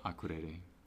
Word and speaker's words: Akureyri: I was listening to another interview with someone that said that like there's a whole new music scene really in Akureyri. – Yes. – Is that how you say it? – Akureyri.